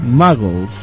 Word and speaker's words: Muggles: ¿Y son mugles los tuyos también? – ¿Mugles, hijo? – ¿Cómo se pronuncia? – Muggles. – Ah, bueno, usted porque es el que habla inglés. Son muggles.